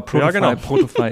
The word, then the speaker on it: Protofy: Spreche ich es eigentlich richtig aus? Würdest du es auch so sagen? Protofy, Protofy? Genauso, genauso würde ich es auch aussprechen.